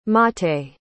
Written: maté

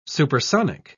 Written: supersonic

